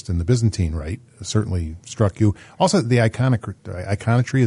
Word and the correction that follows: iconography: Iconography, yeah.